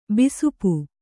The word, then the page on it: ಬಿಸುಪು: bisupu